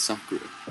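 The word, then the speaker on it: Sacré: The E in Sacre is pronounced. – So no, you're just saying it wrong. It's Sacre.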